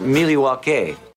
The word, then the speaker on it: Milwaukee: Actually it's pronounced Milwaukee.